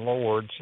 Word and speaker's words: Lourdes: So if you want to go there, the name of the website is Direct from Lords, and you spell that LOURDES.